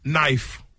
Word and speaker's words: knife: These are English words with silent letter. Knife.